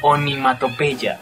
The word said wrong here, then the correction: onomatopeya: Perdón, eso es onomatopeya.